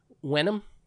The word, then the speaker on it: Wenham: W-E-N-H-A-M. – In Wenham, yeah. Suffolk. Wenham, that sounds about right, yeah.